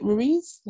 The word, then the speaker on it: Ruiz: is it ruiz how you pronounce yep. his name yep andy ruiz and I, I've, it's like I'm seeing a pattern.